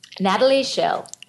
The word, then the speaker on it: Natalie Shell: So when I said that to myself this morning, I thought I sounded very American because I wasn't pronouncing the T, but it sounds like that was okay. And I thought maybe I should be saying Natalie, and I I love that.